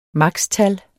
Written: maks.

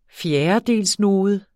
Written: fjerdedelsnode

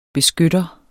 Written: beskytter

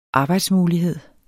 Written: arbejdsmulighed

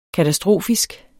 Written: katastrofisk

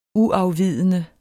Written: UAFVIDENDE

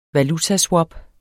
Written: valutaswap

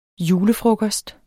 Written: julefrokost

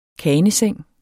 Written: kaneseng

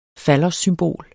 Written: fallossymbol

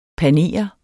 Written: panere

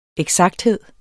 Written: eksakthed

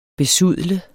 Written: besudle

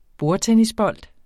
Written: bordtennisbold

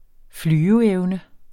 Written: flyveevne

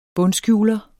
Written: bundskjuler

